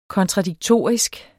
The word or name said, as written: KONTRADIKTORISKE